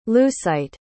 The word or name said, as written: lucite